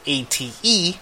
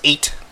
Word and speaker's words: ate: If you didn't know, my cousin, in the Filipino culture, we call older people of our generation that are female ate, which is spelled ATE. Which, you know, in English is pronounced eight, but in Filipino and Tagalog it is pronounced ate.